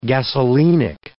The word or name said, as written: gasolinic